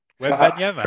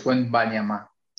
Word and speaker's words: Wembanyama: Wembanyama. First of all, okay, that's how you pronounce his surname. – Wembanyama. Wembanyama. Very French name, by the way. – So it's not Wembanyama. – Wembanyama. – It's Wembanyama.